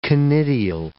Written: conidial

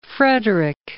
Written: Frederick I

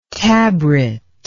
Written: tabret

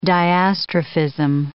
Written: diastrophism

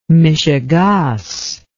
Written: mishegaas

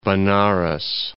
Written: Benares

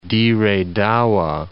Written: Dire Dawa

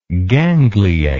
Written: gangliate

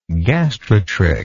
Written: gastrotrichan

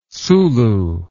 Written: sulu

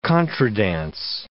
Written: contredanse

